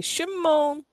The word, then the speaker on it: Chamone: It sounds like how Michael Jackson says, Chamone.